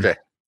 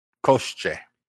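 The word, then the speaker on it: Koshchei: The whole story is about Koshi the Undying, right? Is that how you say his name? Koshi the Undying? – Koshche. – What is it? – Koshche. – Koshche the Undying,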